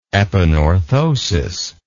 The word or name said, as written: epanorthotic